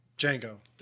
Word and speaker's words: Django: Django, the D silent.